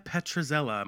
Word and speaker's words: Petrzela: We are so excited to introduce, this is the best name in the world, by the way, Natalia Petrzela.